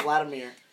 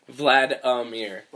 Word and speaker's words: Vladimir: With Vladimir. Make sure it has three syllables. – Vladimir.